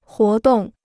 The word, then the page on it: 活动: huó dòng